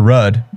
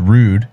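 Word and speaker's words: Ruud: Casper Rudd, actually pronounced Rude.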